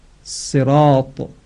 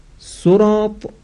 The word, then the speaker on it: صراط: یا اگر نمازگزاری کلمه صراط را در واقع صاد رو برای اینکه بخواد درست تلفظ بکنه به شکل سین ضمه‌دار تلفظ بکنه، باز معنای کلمه تغییر پیدا میکنه.